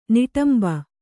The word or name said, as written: ನಿಟಂಬ